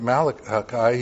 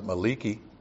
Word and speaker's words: Malachi: So what God is saying through his prophet Malachi, he's saying, or as one guy pronounced it, Maliki,